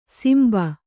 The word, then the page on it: ಸಿಂಬ: simba